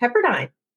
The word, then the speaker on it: Pepperdine: Pepperdine, an American law school. – Interesting. Did I pronounce it correctly or should I repronounce it again? – Oh, no, it's fine.